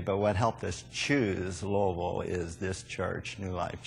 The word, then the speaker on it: Louisville: And when I first came here, it was Louisville, and then I went to Louisville, and then I started saying it properly, Louisville. And I think that's how you say it.